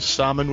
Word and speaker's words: salmon: They'll just pronounce salmon with L in it.